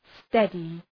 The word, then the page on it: steady: ‘stedı